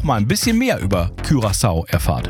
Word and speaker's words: Curaçao: Hallo, und wir haben schon mal rausgefunden, es heißt Curaçao, nicht Curaçao. – Richtig. – Auch wenn das der eine oder andere vielleicht denken mag, Curaçao ist die richtige Aussprache.